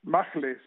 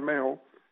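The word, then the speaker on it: Mahle: Tyler Mayo, Magle se dice, pero se pronuncia Mayo.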